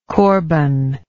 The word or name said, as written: corban